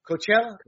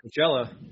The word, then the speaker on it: Coachella: Coachella? – Coachella. Coachella? I haven't been to Coachella in a long time, but I think that's how you say the name. – So the ICE arrest of Coachella councilwoman Yadira Perez's husband, a Mexican national with a criminal pass, has ignited debate over immigration enforcement and sanctuary state tensions.